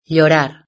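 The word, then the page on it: llorar: Spanish (Spain)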